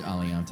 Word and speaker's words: Aliante: Did I pronounce that correctly? – It's Aliante.